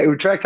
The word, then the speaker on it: Utrecht: Utrecht. Did I say that correctly? – You got that? Yeah, Utrecht, Holland.